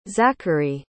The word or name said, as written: zackary